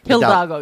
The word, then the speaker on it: Hidalgo: Hidalgo, yeah.